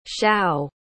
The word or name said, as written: shough